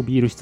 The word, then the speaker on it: 麦酒: 永泰 2 丁目の永泰執行ビール室さんです。麦酒ですね、麦酒と書いてビールと読みます。